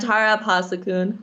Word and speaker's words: Tara Abhasakun: I'm Tara Abhasakun.